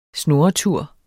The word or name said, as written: snurretur